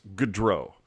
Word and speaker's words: Gaudreau: Can somebody explain to Kenny Albert the difference between Barkley Gaudreau and... or Barkley Goodrow and Johnny Gaudreau. Like, there is a difference between the pronunciation, is all I'm saying. There's Goodrow and there's Gaudreau.